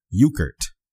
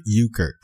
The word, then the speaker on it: Euchert: Now on that website, there was a post by our sister, Jennifer Euchert. That's how I'm going to say her name is pronounced. – Yeah. – It's spelled Euckert. Jennifer Euchert.